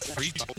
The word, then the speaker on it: frites: Did you know the French don't even pronounce the S? They just say steak frites.